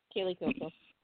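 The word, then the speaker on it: Kaley Cuoco: Kaylee Coco.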